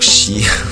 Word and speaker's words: She: Alors effectivement, maintenant on va écouter She. Donc She, pour les romans, je me permets de dire que ça s'écrit S-H-E, donc L en fait en anglais.